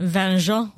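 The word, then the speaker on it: vin jaune: How do you say it? Vinjon? – Vinjon.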